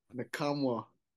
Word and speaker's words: Nakamura: How do you pronounce his last name? – Who? – Mukamura. Is that how you pronounce his name? – Who? All. – Yeah. – I'm Tennessee? – Yeah. – Nakamura. – No, I thought it was like a Nukamura or something.